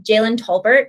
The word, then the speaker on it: Jalen Tolbert: Um, Jalen Tolbert, is that how you say his name? Mm-hmm uh, was a healthy scratch from the lineup.